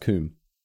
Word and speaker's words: cwm: A coom is a steep-walled semicircular basin in a mountain, sometimes containing a lake. It is spelled C-W-M. – Oh, like quim, but it's pronounced coom.